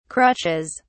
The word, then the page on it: crutches: British English